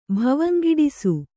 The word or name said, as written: ಭವಂಗಿಡಿಸು